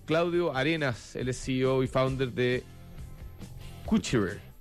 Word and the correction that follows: Qscire: Escúchale, lo dije mal, a ver, ¿cómo se pronuncia el nombre, Claudio? – Tenemos uno de los fundadores que es fanático del latín, así que le gusta llamarlo Cuchire. – Cuchire. – Es un tejillo italiano, es el latín. – Claro, exactamente. – Cuchire se escribe Q-S-C-I-R-E, para los que no los conocen.